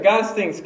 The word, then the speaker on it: Gottesdienst: Gottesdienst. – That's right. Gottesdienst.